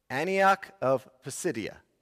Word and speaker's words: Antioch of Pisidia: Antioch of Pisidia is, I believe, how you say the city.